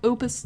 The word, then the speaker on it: Op.: I am told that the way you pronounce OP in music is actually Opus. I'm still watching Talk Opus Destiny, and I knew it was a mixed media project, but I didn't realize the smartphone game hadn't come out yet.